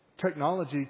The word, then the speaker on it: technology: Because technology. I put the emphasis on the wrong syllable there for a second. Technology can't overcome certain limitations.